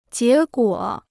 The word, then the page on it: 结果: jié guǒ